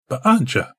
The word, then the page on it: b′a-ja: Galindri